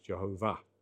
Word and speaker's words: Jehovah: And he was convinced that it should be pronounced Jehovah. So that was interesting.